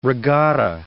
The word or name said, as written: regatta